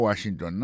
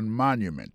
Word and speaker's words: Washington Monument: si n'a mettre accent sur accent encore ou après que mot Washington là accent tombé sur premier syllabe là pour mot monument il tombé sur premier syllabe là de Washington Monument monument c'est comme ça on dit monument en anglais alors place ça c'est place monument ou c'est obélix là.